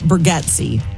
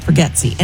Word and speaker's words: Bargatze: It's spelled Gotze, but he calls it Bargatze, like forgets. Bargatze.